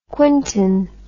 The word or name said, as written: quintin